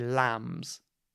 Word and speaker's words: lambs: And remember, the B here is silent. We don't say lambs, we say lambs. The B is silent.